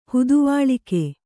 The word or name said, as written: ಹುದುವಾಳಿಕೆ